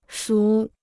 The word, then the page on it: 熟: shú